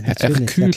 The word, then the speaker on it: Hercule: Und ähm, so in die Richtung, äh, ja, Hercule Poirot, genau, mit H, Ach, also Herkules. Hercule ja, Poirot. Ja, ja.